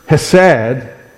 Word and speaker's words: hesed: It's the word, and the best I can do to pronounce it, it is the word H-E-S-E-D, pronounced hesed.